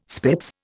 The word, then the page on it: spitz: Indian